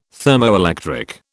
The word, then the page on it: thermoelectric: British English